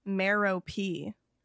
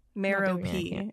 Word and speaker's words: Merope: And I went online. And found the actual pronunciation, and it's Merope. Merope is like the Greek.